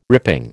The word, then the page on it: ripping: American English